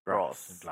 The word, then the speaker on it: grass: グロス。グラス。 – グロス。 – そう。 – え？そう。 R の方がグロスだよね。 – あの方がグラス。 – グロスでしょ。で、 L の方がグラスでしょ。 – グラス。 – But an R, with an R, you pronounce, you have your tongue at the back of your mouth. With an L, the tongue's at the front of the mouth.